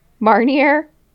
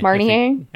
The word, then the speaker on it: Marnier: A glass of 100-year-old Grand Marnier. Did I say that right? – Marnier. I think it's Marnier.